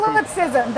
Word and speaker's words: solipsism: Solipsism. That is solipsism. I guess that's how you would pronounce it. – That is how you pronounce it.